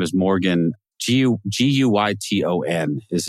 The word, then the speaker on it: Guyton: How, how do you say his last name? Guyton. Uh, Guyton, Guyton. Yeah. – Guyton. Mm-hmm. Okay, I would have said Guyton.